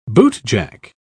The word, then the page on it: bootjack: British English